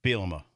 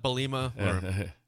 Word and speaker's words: Bielema: Bielema. That's how you pronounce it. – Yeah. – Bielema. – Not Bielema.